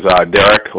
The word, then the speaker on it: Derek: My name is Derek, last name Dawson. – Could you please spell it for me? – All right. D-E-R-E-K.